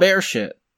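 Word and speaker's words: Beresheet: As far as I know, it's pronounced bear shit, okay? – I'm gonna call it the Israeli mission.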